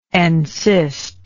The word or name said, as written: encysted